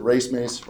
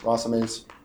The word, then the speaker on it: racemase: It's called racemase. And some people pronounce it racemase, racemase. Basically,